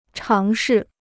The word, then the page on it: 尝试: cháng shì